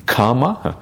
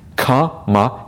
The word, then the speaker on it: kama: It is not to be confused with the word kamma. Kamma is one word, kama is another word. There's a slight difference in pronunciation that is often missed by people who don't have an Indian background. And the kamma is a double M, and it's pronounced as if kamma, twice. Kama is a long A sound, and it's different.